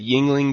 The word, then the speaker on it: Yuengling: you haven't had Yingling beer, which is spelled Yuengling, it is actually the oldest brewery in America currently.